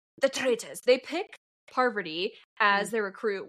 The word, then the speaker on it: Parvati: It's Parvati.